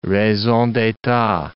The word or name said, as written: raison d'etat